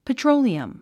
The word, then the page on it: petroleum: pətróuliəm; ペチョロゥリアム